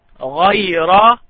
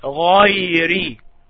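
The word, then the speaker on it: غيرِ: غير. That's a mistake. The correct way is to say غيري.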